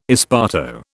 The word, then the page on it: esparto: British English